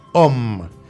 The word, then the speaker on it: homme: Se escribe H-O-M-M-E. En français, nosotros no decimos M-M o L-L o N-N, no. Nosotros decimos O, no, H-O-M-M. Doble L, doble R, es doble. Nosotros no decimos M, M, no. Es H, O, doble M, E. Y se pronuncia OM, que quiere decir hombre.